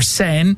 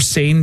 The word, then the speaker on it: Seine: The river that goes through Paris, you passed on that, it's the Seine, the river Seine, or Seine, depends how you say it.